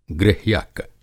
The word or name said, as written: गृह्यक